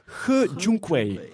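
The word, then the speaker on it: He Jiankui: Eh, vol, als ik het juist gelezen heb in zijn sms, zou de correcte uitspraak Hu Junkwei zijn. nee, ge was het een hele zachte G. Ge Junkwei.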